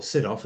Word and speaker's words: Sydow: And Max von Sydow, or Sydow, as I think it's pronounced, was actually quite young at the time.